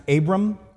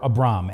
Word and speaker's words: Abram: Now, just to let you know, it can either be pronounced Abram or Abram, and I go back and forth, and I'm probably going to go back and forth during this sermon.